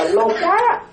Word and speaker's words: Loka: Thì đó, chúng ta còn ở trong ba cái cõi giới này, mà tiếng Pali nó gọi là Loka, nó gọi là Loka. Loka, quý tử đọc ra đó, nghe đọc là Loka, mà quý tử đọc không được đó, quý tử nó một Loka.